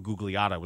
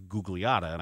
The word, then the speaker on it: Gugliotta: It's like Tom Gugliotta was always Gugliotta. And I asked him, I said, why do we pronounce the G? Because you probably shouldn't. He said, it's Gugliotta. But everybody here pronounces the G.